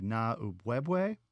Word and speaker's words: Na'ubwebwe: And forgive my pronunciation of this string figure, but it's the Na'ubwebwe. And that's close. Yeah. – Is that close? – Close enough. Yeah.